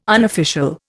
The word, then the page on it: unofficial: Indian